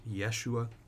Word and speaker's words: Yeshua: It comes from the Hebrew name Joshua, which would be pronounced Yeshua, and it means He saves.